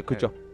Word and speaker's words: Cujo: Pero primero lo miran como bien grandote, como Cujo. – El cujo del Stephen King, o cómo se dice. – Sí, el cucho.